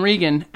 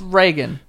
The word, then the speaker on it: Reagan: We are not confined by the way words are pronounced in order to rhyme them. So Tegan and Reagan absolutely work. – Well, it's Reagan.